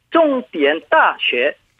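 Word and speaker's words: 종디엔따쉐 (重点大学): 중점대학, 중국어 발음은 종디엔따쉐.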